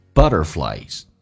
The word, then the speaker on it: butterflies: 第一个单词 butterflies. Butterflies cannot see their wings. 很简单，蝴蝶看不到它的翅膀。尖角的 R 趋向于在美式发音发 E 这个发音叫 butter. 两个元音中间所加的 T 要被浊化成 D 这个发音，在美式发音叫 butterflies.